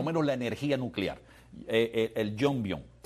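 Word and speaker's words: Yongbyon: ¿Es así se pronuncia? Jongbyon, ajá. – Exacto.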